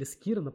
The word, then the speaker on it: Скирон: Вот, и, соответственно, он точно так же убил и вот этого вот Фея, и потом убил э, Скирона или Скирона, по-моему, все-таки Скирон правильно произносится его имя, правильное ударение.